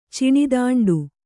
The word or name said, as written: ಚಿಣಿದಾಂಡು